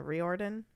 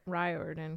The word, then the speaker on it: Riordan: Riordan? Rick Riordan. – Sure. – You got me. – They got him really involved in it. And I think. – Like Riordan, because it's a long I, but I don't know.